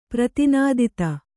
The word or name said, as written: ಪ್ರತಿನಾದಿತ